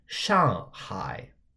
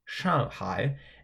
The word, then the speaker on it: 上海: So the word for Shanghai in Chinese is kind of the same thing, but it's pronounced in the 4th tone and in the 3rd tone. Shanghai. Shanghai.